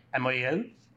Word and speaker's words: Amouyel: I am, once again, at an Exes Summit, and I'm interviewing my good friend, Alex Amouyel. Did I pronounce that right? – Amouyel. – Amouyel? – Yeah.